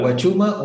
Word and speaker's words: Huachuma: Huachama, the other plant. – Huachuma or San Pedro.